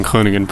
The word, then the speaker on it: Groningen: Thank you for pronouncing Groningen properly.